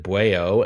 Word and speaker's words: Bwao: Calloway, the brainchild of brothers Reggie and Vincent Calloway, reuniting their talents on their latest track entitled Bueo, spelled B-W-A-O, but they pronounce it Bueo,